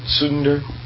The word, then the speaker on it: Tsunder: T-S-U-N-D-E-R. Sunder.